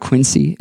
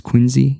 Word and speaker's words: Quincy: It's not Quincy. It's Quincy. The C is as a Z sound.